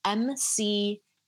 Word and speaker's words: McEwen: And that's, my last name is spelled McEwen.